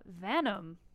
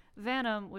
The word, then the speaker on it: Vanum: This is off of their only release, Venom. No, I'm butchering that. It's Latin. Vanum. Close. Oh. But no cigar. Vanum, which is Latin for vein.